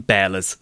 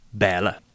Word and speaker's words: Barle: But what we do know is what happened next in Bearla's story. That's spelt B-A-R-L-E and pronounced Bearla.